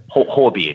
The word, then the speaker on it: Huobi: Huobi, is it pronounced Huobi, is it? Sorry. – Huobi it is, yeah, exactly. – Huobi, right, okay. Looks like Huobi, but Huobi, okay, got it.